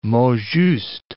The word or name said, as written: mots justes